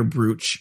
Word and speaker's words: brooch: Is that how you say it? Brooch? Yeah, diamond brooch. – Did you brooch. – Say brooch? – I said brooch. – Oh, my God. You say brooch, are you a Canadian?